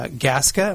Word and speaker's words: Gasca: My guests uh, are Blake uh, Gasca.